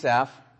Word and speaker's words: Asaph: And I'm going to call him Asaph. That's really not his Hebrew pronunciation, but that's how we all know him. It's Asaph.